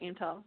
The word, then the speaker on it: Aimtel: Yeah, that's right, Aimtel. Yep. – Okay. Yeah. So like aiming high. – It's like Aim Plus Digital. High, exactly.